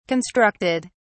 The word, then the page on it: constructed: British English